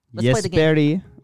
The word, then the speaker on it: Jesperi: Jesperi.